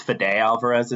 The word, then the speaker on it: Fede Alvarez: I do like Fede, I think it's Fede Alvarez is how you say his name.